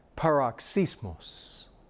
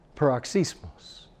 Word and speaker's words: paroxysmos: Pardon me, how you pronounce that word in the original. You pronounce it this way. Paroxysmos. Paroxysmos.